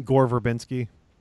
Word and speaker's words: Gore Verbinski: Gore Verbinski.